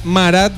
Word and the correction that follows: Murat: No, no, no, Murat.